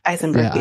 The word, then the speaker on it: Eisenberg: Is it Eisenberg? – Eisenberg. Yeah, he's Jewish, Eisenberg.